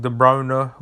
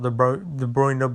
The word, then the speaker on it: De Bruyne: With guys like De Bruyne, which, yes, that's how you say his name, is not De Bruyne, because that's how he said it himself in an interview.